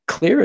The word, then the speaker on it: Xlear: Now, it's spelled X-L-E-A-R, so people know X-L-E-R.